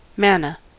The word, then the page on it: manna: American English